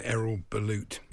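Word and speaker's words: Erol Bulut: But it is very interesting that the Cardiff manager, Errol Bulut, that's how you pronounce it, wants to see VAR in the Championship.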